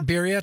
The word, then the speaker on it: birria: I made, I might pronounce this wrong, birria. Birria? – Birria tacos.